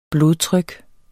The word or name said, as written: blodtryk